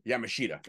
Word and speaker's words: Yamashita: Hope I'm saying this right. Miyu Yashimata. Yashiata. – Yamashita. – There we go.